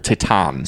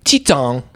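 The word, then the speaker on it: Titane: It's probably called Titan. – Spell it, and I might be able to help you. – T-I-T? Like tit? – Tit. – And then A-N-E, like Ain. – Titon. – Titon? – Maybe.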